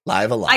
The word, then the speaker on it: Live A Live: I checked because I'm reviewing it for IGN and I have to do a voiceover for a script and I better say it right or the comments are going to be mad. It's live alive.